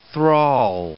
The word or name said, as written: thrall